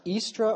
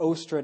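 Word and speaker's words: Eostre: So anyway, it's pronounced Istra or Ostra, depending if you're an Anglo-Saxon or if you're a Roman or, you know, the different pronunciations.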